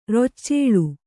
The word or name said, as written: ರೊಚ್ಚೇಳು